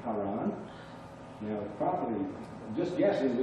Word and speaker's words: Haran: So Haran is the way we kind of pronounce it.